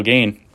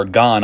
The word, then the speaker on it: Gane: And the main event, Jairzinho Rozenstruik versus Cyril Gane or Gone, I should say, is the correct way to pronounce his name.